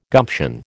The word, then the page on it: gumption: American English